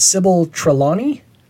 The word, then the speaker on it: Trelawney: Okay, so I'm sure I'm going to butcher this pronunciation, but Sybil Trelawney.